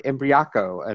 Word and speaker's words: Imbriaco: And welcome, Mark Imbriaco.